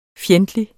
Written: fjendtlig

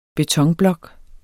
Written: betonblok

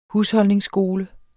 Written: HUSHOLDNINGSSKOLE

